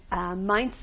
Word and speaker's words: Mainz: Mayence is the Hebrew pronunciation of the German city of Mainz. Mainz, M-A-I-N-Z, in its contemporary spelling, which was one of the well-established communities of Germany, of German Jews at that point.